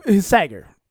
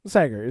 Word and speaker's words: Sagar: No, Sagar. Sagar is how you say his like first name.